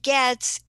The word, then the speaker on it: Goetz: But the spelling of my name is B-R-A-C-H-A. That's Brucca. And Goetz is G-O-E-T-Z. You don't pronounce the O, but it's G-O-E-T-Z.